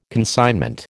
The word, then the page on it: consignment: American English